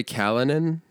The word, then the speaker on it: Callinan: Callinan, which you expected to be Callahan or something.